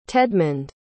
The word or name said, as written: tedmond